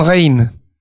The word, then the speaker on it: Rijn: C'est comme une il s'appelait Rembrandt van Rijn. – Ah. – En van, V-A-N, et R-I-J-N, qui se prononce R-I-J-N.